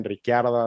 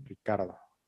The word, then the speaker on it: Ricciardo: Es que es como, algunos lo pronuncian Ricciardo, otros Ricardo. Entonces, creo que él lo menciona Daniel Ricardo, creo yo.